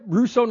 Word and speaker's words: Ruso: Russo, North Dakota. – I'm learning R-U-S-O. – R-U-S-O.